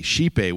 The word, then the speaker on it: scipe: W-E-O-R-T-H, werth. Which, of course, means worth. And shipe, S-C-I-P-E, shipe, which means something like shape or quality. So as an example, we can see the evolved Old English word shipe,